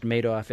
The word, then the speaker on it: Madoff: Well, indeed, the Madoff case, and that I'm understanding is pronounced Madoff as in Madoff with all the money, was a $50 billion and climbing loss estimate.